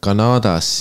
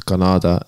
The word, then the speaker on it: Kanada: Aga sa tegid Kanadas siis, noh, eestikeelne korrektne hääldus, Kanada, ära unusta. – Kas on kahe aga? – Jah. – Ei ole. – Kirjas ei ole, aga häälduses on.